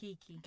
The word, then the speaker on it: Keke: Kiki. – Is it Kiki? – Yeah. – Because it's spelled K-K-A, so I wasn't sure.